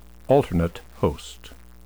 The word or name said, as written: alternate host